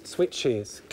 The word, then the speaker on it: switches: E wird eingefügt, ausgesprochen wird es wie is. Switches.